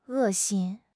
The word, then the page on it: 恶心: HSK6